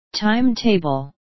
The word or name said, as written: timetable